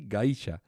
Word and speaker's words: Gaisha: Nintendo Kabuchiki Gaisha. Vale. No tiene más... – ¿Gaisha como geisha o otra gaisha? – Igual, pero con A en vez de con E.